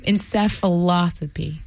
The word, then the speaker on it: encephalopathy: Encephalopathy? Encephalopathy.